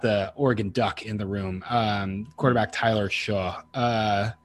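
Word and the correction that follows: Shough: There's a cut at the end. It's like shug. – That's a shug, I think it's good.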